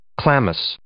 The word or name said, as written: chlamys